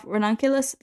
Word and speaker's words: ranunculus: Oh, ranunculus, that is an excellent choice. Excellent pronunciation as well of said flower.